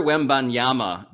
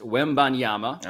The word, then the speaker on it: Wembanyama: So Victor Wembanyama is the kid from France. Wembanyama.